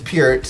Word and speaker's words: Peart: tribute to Rush and the late great Neil Peart. I hope I'm saying it right. Mm-hmm. I was calling him Neil Peart for the longest, right? – But he was Peart, so I hope I'm saying it correctly.